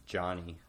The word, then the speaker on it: Jani: Audiobook, it was Johnny. – Yanni. – Johnny. – Okay. – Cooperstown. – It's spelled J-A-N-I on the page.